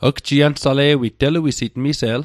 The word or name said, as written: gjiansale'wit